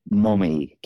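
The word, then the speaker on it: momme: mommy count, and that's M-O-M-E, but it's pronounced mommy.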